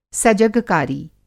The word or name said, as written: सजगकारी